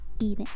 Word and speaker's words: Eden: Eden, perdón perdón. Si quiero pronunciar inglés, debo hacerlo bien. Así que ide.